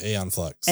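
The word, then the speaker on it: Æon Flux: Is it Xeon Flux? Is that how you say it? – Aeon Flux.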